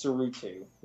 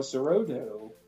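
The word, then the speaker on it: Tsuruta: I guess it's Sarutu. It's T-S-U-R-U-T-A. I'm going to say that initial T is silent.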